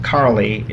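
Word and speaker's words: Károly: By the way, our school, Karoly Gasper, they pronounce it Carly. It's where we would get the word Charlie. Charles Gasper was like the John Wycliffe of translators for Hungarian Bible.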